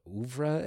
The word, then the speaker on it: oeuvre: Whereas Superbad, also from Judd's, you know, oeuvre, am I pronouncing it right? I'm not smart. – Yes.